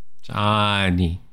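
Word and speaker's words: Johnknee: And I am John. – Neat. Well, Johnny. – I was just talking about how, like, the H isn't silent. It's still there. So people just think I'm not saying my name right.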